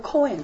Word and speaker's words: Cohen: 如果现在现代，如果你有认识的人是外国人，他的后面的名字叫 Cohen， C-O-H-E-N， H 不发音 Cohen 的话，你可以确定他是犹太人，并且他是祭司的后代。因为这个 Cohen 这个字就是祭司的意思，他们用祭司做他们的 last name，就是他们的姓，你明白吗？所以你仔细去看，只要叫做 Cohen 的，可以很确定他是亚伦的后代，亚伦的后代。